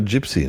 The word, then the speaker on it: Gypsy: Heißt es überhaupt Gypsy? Gypsy? Gypsy? – Ja, Englisch ausgesprochen Gypsy, ne? – Ja.